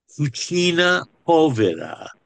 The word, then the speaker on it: cucina povera: And talk about what the Italians call cucina povera.